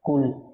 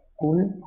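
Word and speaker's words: কুল (قل): বুঝার জন্য একটি উদাহরণ দিচ্ছি, যেমন কি মনে করেন আমরা সবাই ক্লাসে কি পড়ি, কুল না, কুল কফ দিয়ে, তো কুল মানে হে হাবিব আপনি বলুন, কুল কফ দিয়ে হে হাবিব আপনি বলুন। কিন্তু আপনি এটাকে যদি সঠিক উচ্চারণ না করে আপনি যদি বলেন কুল কুল অর্থাৎ কাফ দিয়ে কুল, তখন কি হবে জানেন? তখন হবে আপনি খান।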